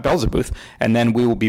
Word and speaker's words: Beelzebuth: It is called Beelzebuth. That's how I say it. It's Beelze, B-U-T-H.